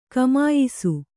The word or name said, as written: ಘಮಾಯಿಸು